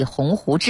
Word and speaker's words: 鸿鹄: 校庆纪念会当天呢，林建华发表题为大学是通向未来的桥这篇演讲，稿里面有一处表述是要立志立鸿鹄志做奋斗者，发言中林建华将鸿鹄的鹄念成了号，引起广泛关注。